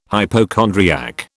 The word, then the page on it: hypochondriac: British English